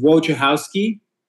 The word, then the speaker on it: Wojciechowski: Uh, close enough. It's Wojciechowski.